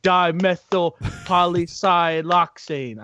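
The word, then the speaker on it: dimethylpolysiloxane: Dimethylpolysioxane. Yeah, I think I've got it right, maybe. – There's an L in there. I think you're missing the L.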